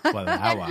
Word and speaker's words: Wallowa: Wallowa. – I can say it correctly. – Wallowa. You're doing so well.